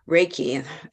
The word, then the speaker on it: Reiki: Reiki, and the best way to remember how to say it is rays of sun. Okay. Everybody feels amazing when they have rays of sun on their body. – Yes. – So it's Reiki.